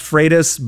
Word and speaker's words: Freitas: Technically, it's pronounced Freitas, but my drill sergeant taught me to respond to anything close. – all right. Freitas is the right way to say it.